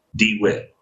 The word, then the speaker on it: DeWitt: DeWitt.